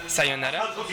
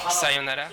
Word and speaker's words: sayonara: Par exemple, l'exemple classique, c'est qu'on a tendance à dire la mauvaise prononciation, c'est... Attention, parce que je ne sais plus prononcer les mots. Ouais. Sayonara? Non, oui, c'est ça. Sayonara, de façon plate, alors qu'en fait, les Japonais mettent un rythme dans le mot, quoi.